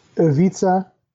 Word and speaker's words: Ivica: What is it? – It's Ivica Zubac, but it's not. I don't know what the hell you said.